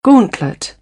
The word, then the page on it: gauntlet: British English; ɔː